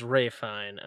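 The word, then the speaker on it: Ralph Fiennes: I believe it's pronounced Ray Fine. I don't know who Joseph Fine is, so I'm going to go Ray Fine.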